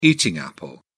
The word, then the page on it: eating apple: British English